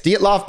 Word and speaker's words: Dyatlov: The uh, Dyatlov. – Is that what it's called? Yes, Dyatlov Pass.